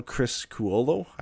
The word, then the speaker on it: Criscuolo: And AHLer Kyle Criscuolo, I think is how it's said. But nailed it. – Yeah, we'll go with that.